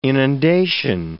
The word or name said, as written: inundation